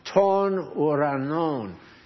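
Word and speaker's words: ton uranon (τῶν οὐρανῶν): Now, it's pronounced, the first underlined phrase, expression there, is pronounced ton uranon. Now, the thing that looks like a V in Greek is pronounced like an N. Where's Tony? He can keep me right on here. Okay, so ton, T-O-N, ur, and the thing that looks like a P is an R, uranon, ton uranon.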